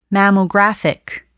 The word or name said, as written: mammographic